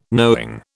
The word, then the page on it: knowing: British English